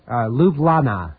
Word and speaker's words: Ljubljana: Ljubljana is the way it's pronounced. – You spell it. Hello? – I'll try. Okay, it's spelled L-J... U-B-L-J-A-N-A.